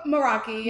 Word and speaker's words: meraki: Is that how you say it? – Meraki. Yeah. So Meraki is, no, you're good. Meraki is pretty much a Greek word that means to put your soul into something that you love.